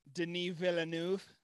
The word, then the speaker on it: Denis Villeneuve: I think that is the correct pronunciation. Denis Villeneuve. – Yeah, something like that.